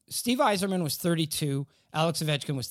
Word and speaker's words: Yzerman: I believe that it's pronounced Steve Yzerman. – Yzerman. That's how Bill Clinton pronounced it.